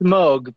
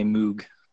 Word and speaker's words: Moog: Because I remember Bob Moog coming on and saying, this is how you pronounce it. – Yeah, it's Moog, but British guys, for some reason, just can't say that. They always say Moog.